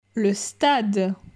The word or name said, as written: Le stade